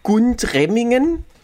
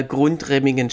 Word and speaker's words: Gundremmingen: Also man hat immer einen Buchstaben damit reingeschmuggelt beim Aussprechen. Also es ging um Gundremmingen. – Sagt mir überhaupt nichts. – Und in der Zeitung stand eben gelegentlich mal Grundremmingen, stimmt aber nicht.